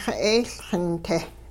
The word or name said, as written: xe'eeLxin-te